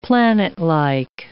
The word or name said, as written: planetlike